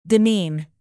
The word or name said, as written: demean